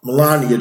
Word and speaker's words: Melania: I, I might say that name wrong. Ms. Melania. Melania, Melania. Trump. Right, Melania Trump.